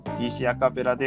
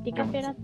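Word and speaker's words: DCappella: DC アカペラでフレンドライクミーでした。あそこね、ディカペラって読む。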